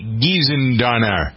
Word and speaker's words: Gissendaner: Kelly Renee Giesendonner. Excuse me, is that how you pronounce her name? G-I-S-S-E-N-D-A-N-E-R? – I think so.